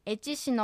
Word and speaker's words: エチシ: はい、発音で注意するのはエチシのシを弱く発音して、はっきりエチシと発音しないことが大切です。 – エチシではなくてエチシですね。 – はい、そうです。